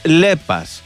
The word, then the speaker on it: Λέπας: Λέπας. Προφέρεται Λέπας.